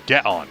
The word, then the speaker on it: Geon: G-E in Japanese is pronounced like get, so Geon, who is 45 meters tall, weighs 40,000 tons.